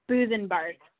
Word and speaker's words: Busebark: Boosenbark? – Boosenbark. – Damn it. – Boosenbark. Yeah, like beer tree.